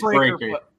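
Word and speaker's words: Breakker: Rick Steiner's son, who is now Braun Breaker with two K's, right? Is that how you pronounce it? Brecker, Breaker? I don't even know. – I think it's Breaker.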